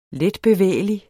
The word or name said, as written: letbevægelig